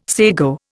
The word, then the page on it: sago: Indian